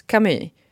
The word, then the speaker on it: Camus: Camus, Camus uttalar man väl.